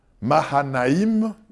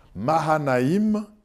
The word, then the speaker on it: Mahanaïm: Et il nomma ce lieu Manaïm, qui veut dire les deux camps. Manaïm, Mahanaïm, je prononce bien maintenant, Mahanaïm, les deux camps.